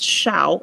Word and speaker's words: Shao: Little fun side note, Ran and Shaw, Ran means burn or ignite in Chinese, and Sha is more correctly pronounced Shao, apparently. I think I said that right.